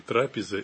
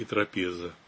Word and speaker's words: трапеза: Вы знаете, вот просто по-славянски, так же, как и по-гречески, ударение подвижно. И в каких-то формах может так звучать, в каких-то так. Но по-славянски привычно больше трапеза. А в нашем обиходном языке, когда мы говорим там, вот, о дружеской трапезе, например, ударяем так. Но вот по-гречески, да, там тоже ударение подвижно. И вот в начальной форме, в именительном падеже, там будет трапеза как раз.